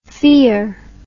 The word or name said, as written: fiar